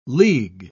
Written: league

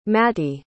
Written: matty

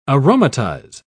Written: aromatize

